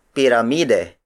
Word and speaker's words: piramide: Lata, the Tagalog word for can, is spelled as L-A-T-A. Again, the Tagalog word for cylinder is cilindro. Up next, we have piramide. Piramide. Piramide. Piramide is spelled as P-I-R-A-M-I-D-E.